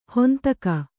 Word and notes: ಹಿಂದೆಗೆ: hindege

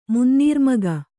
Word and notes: ಮುನ್ನೀರ್ಮಗ: Kannada

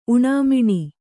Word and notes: ಊನಮಾನ: ūnamāna

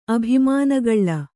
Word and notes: ಅಭಿಮಾನಗಳ್ಳ: abhimānagaḷḷa

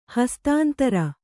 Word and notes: ಹಸ್ತಾಂತರ: hastāntara

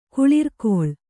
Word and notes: ಕುಳಿರ್ಕೊಳ್: kuḷirkoḷ

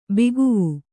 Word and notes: ಭಗವಾ: bhagavā

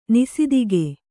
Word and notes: ನಿಸಿದಿಗೆ: nisidige

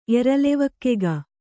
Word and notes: ಎರಲೆವಕ್ಕೆಗ: Kannada

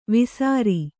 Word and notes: ವೇಸರ: kannada

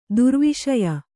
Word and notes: ದುರ್ವಿಷಯ: durviśaya

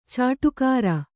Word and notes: ಚಾಡಿಕೋರ: cāḍikōra

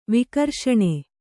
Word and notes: ವಿಕರ್ಷಣೆ: vikarṣaṇe

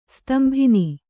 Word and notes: ಸ್ತಂಭನ: stambhana

